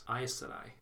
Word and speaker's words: Aes Sedai: Close. Aes Sedai. You said ice.